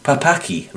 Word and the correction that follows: Pakapi: Records, which is Papaki Records. No, I can't say it right. Pacapi, that's right. Pacapi Records, P-A-K-A-P-I.